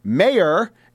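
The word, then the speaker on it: Meijer: I interviewed former Representative Peter Mayer, and I said that correctly the first time, and then I delved into calling him Meyer, Meyer, Meyer. That is so wrong. I was mired in that incorrect pronunciation,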